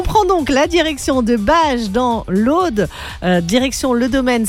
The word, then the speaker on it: Bages: Alors, j'hésite toujours sur la prononciation. Je ne sais pas si c'est à Bages ou Bagès dans l'Aude. En tout cas, j'espère que vous m'en excuserez. Bages, voilà, Marc. Ben voilà, Bages. C'est Marc qui me rectifie.